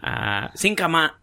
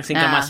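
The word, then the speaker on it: xin cảm ơn: Singkama. Parang singkama. It sounds like singkama. Singkama, something like that.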